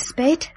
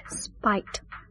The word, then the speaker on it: Speight: I don't think he'll keep you long, Miss Spate. – Spite. I'm sorry. Spite. It's pronounced spite. – It's spelled S-P-E-I-G-H-T. That's usually pronounced spate, isn't it? I thought it was. – It's spite.